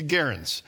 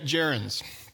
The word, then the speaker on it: gerunds: You look at that word, it should be gerunds. That's the first problem with grammar, is you have this soft G sound. It's gerunds.